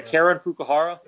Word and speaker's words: Karen Fukuhara: Sorry, Karen Fukuhara? – Yeah, that's it.